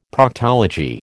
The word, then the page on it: Proctology: American English